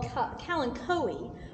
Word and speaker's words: Kalanchoe: The other one is going to be called Kalanchoe, although some people do pronounce it as Kalancho. So it could be pronounced either way.